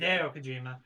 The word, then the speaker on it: Hideo Kojima: Hideo Kojima.